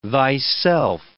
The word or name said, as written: thyself